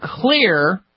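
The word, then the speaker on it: Xlear: Using clear rescue nasal spray, an all-natural, potent, drug-free option against hardcore nasal problems. Clear is spelled with an X, X-L-E-A-R. The X stands for xylitol.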